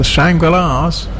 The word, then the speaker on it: Shangri-Las: Well, Sarah says it's pronounced the Shangri-Las.